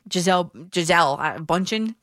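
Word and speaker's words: Bündchen: Giselle Bundchen is how you say her last name, the model.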